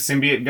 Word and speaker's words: symbiote: There's like the villain is some kind of symbiote guy too. I guess symbiote. – Symbiote. Yes, that's how you pronounce that.